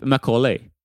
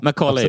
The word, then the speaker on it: Macaulay: Det kan man tänka sig, man står M-A-C-O-L-E-Y typ, Macaulay. – M-A-C-A-U-L.